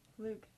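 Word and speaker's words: Pflug: Flug. The P is silent.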